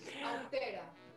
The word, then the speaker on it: austera: Es ah, está difícil, austera. Repite, repite solamente la palabra, austera. – Austera. – Austera. Austera. Imagínate que la palabra lleva un acento en la E.